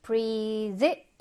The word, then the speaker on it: breathe: 그 다음에 breathe 발음을 좀 주의해야 되고요. breathe 이로 끝나죠? 그럼 동사로 숨을 쉬다인데요. 명사는 끝에 이가 없어. 그 발음도 breath. 좀 달라요. 그래서 얘가 숨, 호흡이 되고, 동사는 breathe 라고 발음을 합니다.